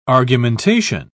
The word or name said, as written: argumentation